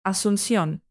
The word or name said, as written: asuncion